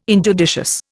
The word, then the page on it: injudicious: Indian